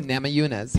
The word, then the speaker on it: Namajunas: Rose Namajunas. How do you say that? – Is it with a J or with a Hunes? – Yeah. Oh, Namajunas.